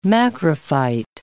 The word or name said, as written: macrophyte